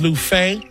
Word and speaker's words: Luffeigh: Last name is Lufay. L-U-F-F-E-I-G-H, and one F is silent.